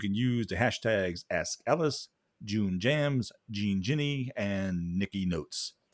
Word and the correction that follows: Jean Genie: Jean Genie.